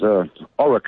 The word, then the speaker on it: Orrock: My name's Oric, actually. It's spelled also difficultly. O-R-R-O-C-K, the founder of an organization called Children Need Both Parents that fights for the rights of access of fathers to their kids.